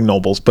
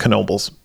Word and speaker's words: Knoebels: It looks like Knoebels, but it's pronounced Knoebels. That's a whole nother story.